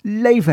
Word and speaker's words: Laufey: And it's by Leve. I'm pretty sure that's how she pronounces her name. It's Icelandic Chinese and spelt L-A-U-F-E-Y, just in case you're wondering.